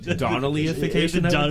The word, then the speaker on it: Donnellyification: I think we, Jesse and I played there once prior to its, like, Donnellification. – Because is that how you say it? – Donnellyification. – Donnellyification. Right.